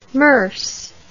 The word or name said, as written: Moers